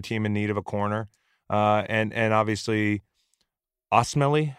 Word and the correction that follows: Osemele: how do you say that name? I've never gotten it. Osemele. – Yeah, Osemele. That one.